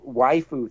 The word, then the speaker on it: waifu: and the whole waifu thing, right? Waifu just is Japanese pronunciation of the English word wife, and so this whole waifu internet waifu thing, or no, what was it?